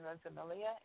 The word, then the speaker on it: Rosamilia: My name is Kristen Rosamilia, and it's spelled CHRISTENROSAMILIA.